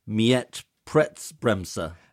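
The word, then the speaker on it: Mietpreisbremse: So in I think you mentioned what's happening in other European countries, but so in Germany they have, and I'm going to get the pronunciation wrong, in Berlin, I think, Mietpreisbremser. – That sounded perfect.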